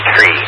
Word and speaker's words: three: And actually, fittingly, thrice, because I forgot three, and not three, our radio operator friend here, but three, the number three in ICAO is supposed to be tree. And yeah, I had it in there for the pronunciation as tree, but not the word. So yeah, three is tree in ICAO.